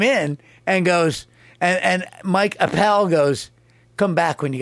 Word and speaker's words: Appel: Because Bruce Springsteen needed a producer, so he goes, and this guy, Mike Appel. Said, it rhymes with lapel.